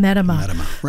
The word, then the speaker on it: Metima: Tom, is it Medima? – Metima. – Tom Metima. – Rhymes with enema.